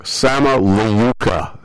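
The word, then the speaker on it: Sama Layuca: The piece of music is called Sama La Kuya, or Sama Le Kuya. And no, I'm pronouncing it wrong. Sama Leuka. Yes, yes. Gee, get it right.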